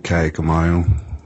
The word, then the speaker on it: Kaaiakamanu: Vincent Keone Kaikamanu. Last is K-A-A-I-A-K-A-M-A-N-U.